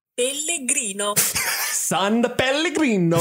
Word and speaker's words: San Pellegrino: San Pellegrino.